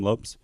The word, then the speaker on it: Lopes: Is that how you say his name? Lopes? – Yep.